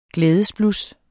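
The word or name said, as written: GLÆDESBLUS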